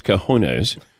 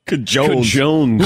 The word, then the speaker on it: cojones: But when he didn't know how to pronounce cojones... – Cajones. – Whoever even said Cajones? – Cajones. Really? – He had never heard of cojones? – He had.